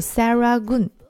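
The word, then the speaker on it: Serangoon: 在这片区域当中，有一条非常主要的商业街，它的名字叫做石龙港路，它的英文名字应该读作 Saragun。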